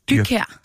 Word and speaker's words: Dyrkær: Sigrid, nu skal jeg udtale dit efternavn korrekt. Sigrid Dyrgaard. – Dyrkær. – Dyrkær.